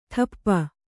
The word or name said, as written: ಠಪ್ಪ